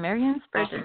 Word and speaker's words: Marian Spurgeon: Yes, Jim, Marian Spurgeon.